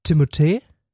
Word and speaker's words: Timothée: Tatsächlich, also er hat es selber in Interviews auch gesagt, richtig ausgesprochen wird sein Name Timothée. – Timothée? – Timothée. Ich habe leider nicht so einen guten französischen Akzent, aber Timothée ist die richtige Aussprache.